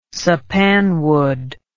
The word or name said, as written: sapanwood